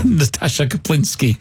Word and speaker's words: Natasha Kaplinsky: Natasha Kopolowski. – Yeah. – Oh, yes. – Natasha Kaplinsky.